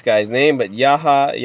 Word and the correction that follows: Yahya Abdul-Mateen: Yahya Abdul Mateen is back as Black Manta, the the villain of the first movie.